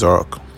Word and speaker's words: Oroque: My name is Orok. Usually spelled O-R-O-K where I'm from, but I spell it O-R-O-Q-U-E.